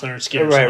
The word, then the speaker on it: Lynyrd Skynyrd: Yeah, pronounced Lynyrd Skynyrd.